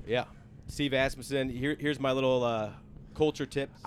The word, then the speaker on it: Asmussen: Steve Asmussen. – Asmussen. – Asmussen. – Yeah. – Asmussen. That's how you say it.